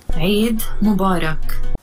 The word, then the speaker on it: عید مبارک: د عید مبارک د صفت او موصوف کلمات عربي دي او هغه کسان چې عربان نه دي دا دوه کلمې د عربانو په څیر نه تلفظ کوي. – عید مبارک. – عربان عین واضح تلفظ کوي ځکه چې دا توری د دوی په ژبه کې شته خو همدا مبارکي چې کله په پاکستان کې خلک ورکوي لهجه بدلیږي.